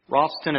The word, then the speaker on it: ratson: This word accepted is the Hebrew 7522, Rothson. Rothson, it's spelled R-A-T-S-O-N. It's kind of pronounced a little bit of a, I don't know, R-A-W-T-S-O-N is how you kind of say it.